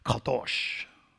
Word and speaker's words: kadosh: Or like the Hebrew writer would, the Hebrew pronunciation would be kadosh.